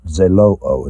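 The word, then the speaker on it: Zelouo (ζηλόω): How do you pronounce it? Zelouo. Zelouo is the way you pronounce it.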